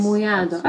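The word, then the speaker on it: Mamoiada: But there's up in the islands, I'd probably pronounce it incorrectly, but Mamoyada. – Yes. – Mamoyada. Okay, perfect. I said it correctly.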